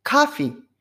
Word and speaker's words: coffee: アメリカ発音だとこれコーヒーって言わないじゃないですか。カフィ、カフィ。かに近い発音になってきますよね。